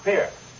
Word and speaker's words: Pierre: Pronounced Pierre. – Pierre, South Dakota. – Pierre, South Dakota. We don't give it the French Pierre, no.